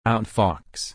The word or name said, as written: outfox